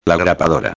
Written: la grapadora

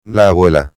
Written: la abuela

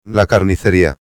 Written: la carniceria

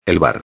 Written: el bar

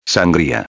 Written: sangria

